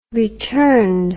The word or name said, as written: returned